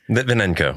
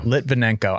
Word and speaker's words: Lytvinenko: Litvinenko. – Litvinenko.